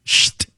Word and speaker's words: Pschitt: It's spelled P-S-C-H-I-T-T. And it is the How would you pronounce that? – Shh. Because that's the sound of opening.